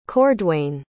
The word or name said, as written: cordwain